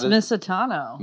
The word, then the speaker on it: Misitano: It's Misitano.